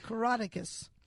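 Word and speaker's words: Coroticus: I usually say a letter from to the soldiers that I can't pronounce. Caroticus. Have I got it? – You got it.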